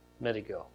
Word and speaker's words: Megiddo: The two together literally mean the mountain of Megiddo. Megiddo. Got to get my syllable in the right place.